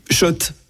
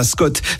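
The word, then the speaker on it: Schott: Oui, Shot et pas Scott. Ça fait des années qu'on prononce mal la marque.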